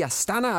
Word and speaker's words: Astana: Is that how you pronounce it? – I believe so. – FC Astana. I don't think you know it with that particular accent, but it's somewhere close to it. – Do it in your Kazakh accent. – If you're an Astana fan, that's how you say it.